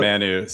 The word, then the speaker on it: Manu: Am I saying it right? – Yeah, what? Manu.